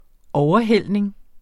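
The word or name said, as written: overhældning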